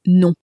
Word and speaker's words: nom: For instance, we might say nom de plume, except we don't pronounce it very correctly because name is pronounced nom. – It's spelt N-O-M, but is M in for luck? No, it isn't. And so the M is not clearly pronounced.